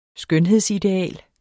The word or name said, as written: skønhedsideal